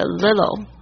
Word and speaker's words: little: 这个 little L-I-T-T-L-E，你仔细听这个美国人美式发音，他们会那个 little 那个 T-T 的声音会浊化掉， little 他会念 little，然后呢比较脆的声音会变成 little，或者念的比较慢的时候才会讲成 little，稍微注意一下 little little 都可以。